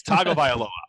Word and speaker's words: Tagovailoa: It's Tagovailoa. I know how to say it. I'm not going to be like the guy at the Heisman ceremony or Stugatz. It's Tua Tagovailoa.